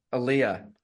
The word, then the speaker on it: Aaliyah: Aaliyah.